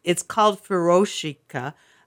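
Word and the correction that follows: furoshiki: Shiki. Sorry. I apologize to our lovely speaker, Hiromi, for mispronouncing that.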